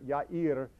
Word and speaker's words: Yair: Jairus comes from the Hebrew word Yair. Yair is a name. You can find it in the Old Testament. My best friend in college's name was Yair, except in English they pronounced it Jair, J-A-I-R.